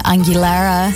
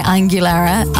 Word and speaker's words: Aguilera: I used to call Christina Aguilera. Aguilera, sorry, Aguilera.